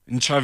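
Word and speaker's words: Ntshavheni: Minister of Presidency is a Minister Machinveni. Did I pronounce it right? – Nchaveni. – Did I pronounce it right? – Minister and the President Nchaveni, yes.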